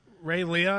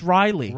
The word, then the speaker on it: Reighlee: Like R-E-I-G-H-L-E-E or whatever. – Yeah, yeah, yeah. – No. – They said Leah. – Ray Leah or something? Ray Leah? – No, that's just Riley. – Ray Liotta? – It's just pronounced Riley.